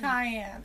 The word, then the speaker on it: Kiyan: Enough, it's Kiyan. – It's Kiyan, like the pepper.